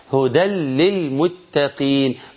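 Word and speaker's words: هدًى للمتقين: يقول ده إدغام، النون أضغمت في اللام وأصبحت حرف جديد تماما، وأصبح ما فيش حرف خالص، ولام هي اللي عندي لام مشددة، ودي اللي أنا بنطقها. هدل للمتقين، هدل بعد الدال شيل التنون وحط لام، هدل للمتقين.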